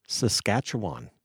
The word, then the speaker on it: Saskatchewan: Today, we got across the table from us Jay Took, whose musical journey began in his childhood home of Weyburn, Saskatchewan. – You actually said it correctly.